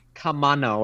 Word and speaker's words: Kamino: He said it like Kamano or something.